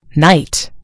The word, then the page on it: knight: British English